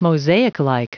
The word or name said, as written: mosaiclike